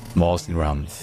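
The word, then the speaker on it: Mawsynram: Par contre en anglais, donc l'autre langue nationale de l'Inde, ça se dit Mawsinram. Mawsinram. Mawsinram.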